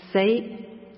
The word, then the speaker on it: sei: Metta is metta, and sei, that's the Burmese pronunciation of the Pali word citta.